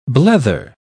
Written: blether

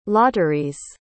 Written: lotteries

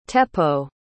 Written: teppo

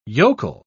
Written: yokel

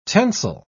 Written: tensile

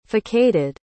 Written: facaded

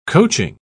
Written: coaching

